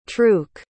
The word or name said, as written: truk